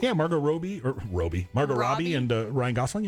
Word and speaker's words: Margot Robbie: Those certain people include Margot Robbie.